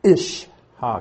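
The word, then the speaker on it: ish-ha (אשה): Aleph, it's a glottal stop. Again, it's very hard to pronounce. It's just ish-ha, because the I sound comes from that little dot underneath it. So it's ish-ha. But it's an aleph.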